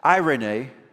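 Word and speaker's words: Irene (εἰρήνη): And the word that we have for peace in ancient Greek, I gave you the pronunciation, it's pronounced Irene.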